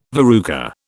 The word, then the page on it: verruca: British English